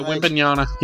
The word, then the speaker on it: Wembanyama: Coming up next, I said we got our quote of the week, and we're going to be discussing Victor Wembanyama. I wonder if I said that right. Did I say that? – Yeah, Wembanyama. Yeah.